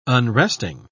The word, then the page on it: unresting: British English